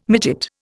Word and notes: midget: Indian

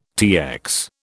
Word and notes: TX: British English

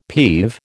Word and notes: peeve: American English

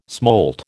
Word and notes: smolt: American English

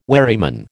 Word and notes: wherryman: American English

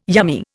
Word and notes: yummy: Indian

